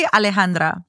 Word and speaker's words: Alejandra: Wait, technically Alejandra. Someone was like, she doesn't even say her own name right. I was like, don't make me pop off on why I have to say it like that.